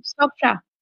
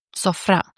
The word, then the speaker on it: Sofra: Sofra. – Sofra. Aber ist die Betonung am Ende und man macht ein Rollen? – Ja, in türkischen Wörtern ist die Betonung oft am Ende. Sofra. – Sofra.